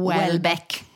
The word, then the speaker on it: Houellebecq: Eh, och vi har lärt oss hur det ska uttalas. – Welbeck.